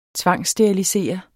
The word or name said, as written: tvangssterilisere